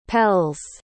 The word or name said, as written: pelles